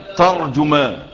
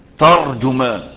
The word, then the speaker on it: ترجمان: وهذه الكلمة أهل اللغة ذكروا فيها أربعة أوجه في نطقها. ترجمان زي ما أنا سمعتها لك، ترجمان زي ما أنا سمعتها لك، وترجمان هذه لغة تانية، وترجمان، وترجمان. كم لغة؟ أي صورة من الصور الأربعة نطقتها تبقى صح. الأشهر الغالب فيها الفتح، ترجمان، وهي اللغة أو الصورة التي ورد بها الحديث عند البخاري وعند غيره، والصورة الغالبة على ألسنتنا ان احنا بنقول ايه؟